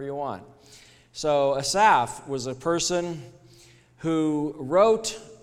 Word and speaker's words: Asaph: Asaph is probably how you pronounce A-S-A-P-H. In Hebrew, it is traditional to put the emphasis on the final syllable of Hebrew names.